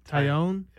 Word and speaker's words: Taillon: How do you say it? – Taillon.